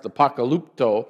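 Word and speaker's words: apokalypto: It's pronounced apocalypto.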